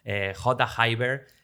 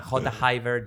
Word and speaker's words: J'hayber: Is there multiple ways you can pronounce it, or is there just one way and that's the way I will say it's one way, but it's true that Spanish people tend to pronounce the J very jota, very hard, but jota Haber.